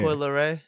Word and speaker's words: Coi Leray: Coi Leray? – Oh, yeah. Coi Leray.